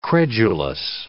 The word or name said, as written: credulous